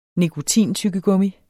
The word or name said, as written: nikotin